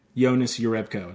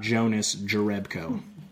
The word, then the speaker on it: Jonas Jerebko: They're both soft J's. I think it's Jonas Rebko. – Jonas Rebko. It's not Jonas Jerebko.